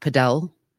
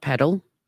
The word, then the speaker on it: padel: But yes, apparently it is pronounced padel, not paddle.